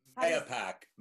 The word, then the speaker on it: Mahopac: So now you all are we're doing this live from, I'm gonna say, I don't know how to say this, Mayopec, Mayopec. – Mayopac. Well, Mayopac is how it was pronounced when we moved here 20 years ago, but we were told the old timers say it more the Native American way, which would be Mahopac.